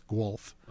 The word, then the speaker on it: Guelph: Guelph. – Spell. – Oh, I don't know how to spell it. – Okay. – It's like Gulf, but it's Guelph.